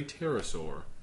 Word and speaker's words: pterosaur: Not the one I believe the most, but my favorite one is that it is a pterosaur. – Ooh. – This goes back to your raptor. – I'm really pleased that you did not pronounce the P like I would have.